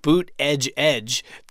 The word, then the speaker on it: Buttigieg: he had people on stage leading chants of boot edge edge to help people pronounce his name.